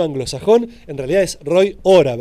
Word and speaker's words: Hora: ¿Cómo se pronuncia exactamente el apellido? Hora. – Bueno, depende, ¿viste? Acá se pronuncia así, en otros lugares se pronuncia distinto. – Perfecto. El Howrah no estaba tan mal entonces. – No, es así. En Inglaterra es así.